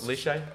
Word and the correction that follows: Lycée: What's the school? – Liche? – No, your school. Was it called like? – Condorcet. – Lycée. Yeah.